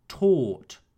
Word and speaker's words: taught: Teach in the simple past tense is pronounced taught. I taught the students.